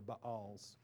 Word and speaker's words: Baal: He does say, however, I've saved 7,000 who have not bowed a knee to the Baals, B-A-apostrophe-A-L, which pronounces weird in English, which you pronounce weird in English, Baal.